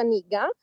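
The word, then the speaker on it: nigga (那个): è una parola cinese che si pronuncia nigga, che suona molto come la parola...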